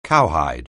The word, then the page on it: cowhide: anglais